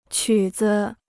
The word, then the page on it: 曲子: qǔ zi